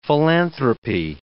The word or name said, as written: philanthropy